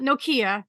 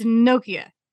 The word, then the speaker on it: Nokia: Here's where I step in and go, it's Nokia. You know, it's not Nokia, it's Nokia. – Nokia. – Yes. First syllable is stressed.